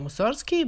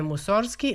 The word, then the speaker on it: Musorgski: I sam Musorgski je više puta insistirao na izgovaranju glasa G u njegovom prezimenu, koje ga je ne samo odvajalo od neprijatnih asocijacija, već i upućivalo na njegovo pravo značenje, a ono je poticalo iz crkvenoslovenske reči musorga, koja znači muzičar.